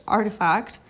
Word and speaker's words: RTFKT: Nike hasn't sold anything yet on Roblox, but in December it acquired RTFKT, pronounced Artifact, a collective of digital designers best known for metaverse-ready sneakers and collectibles for an undisclosed sum.